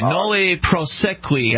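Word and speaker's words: nolle prosequi: Okay, nulli prosequi, I believe, is how that is pronounced.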